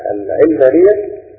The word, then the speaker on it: العلمانية: فكلمة العلمانية مش مأخوذة من العلم، مأخوذة من العالم، العالم، العالم. واضح؟ فأدق النطق أن احنا نصوت عليه في الخرطة بدل ما نستعملها في كسر العين الذي بيخدع الناس ويزينوهم من العلمانية مأخوذة من العلم. لا، هي مأخوذة من كلمة العالم. العالم بمعنى هذا العالم، الحياة الدنيوية، الدنيا.